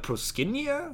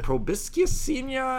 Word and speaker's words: Proscenia: Is that proboscis senia? And I'm like, no, it's not a proboscis. Proscenia. – But it makes don't pronounce the C, just say proscenia.